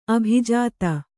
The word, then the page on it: ಅಭಿಜಾತ: kannada